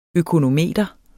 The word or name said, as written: økonometer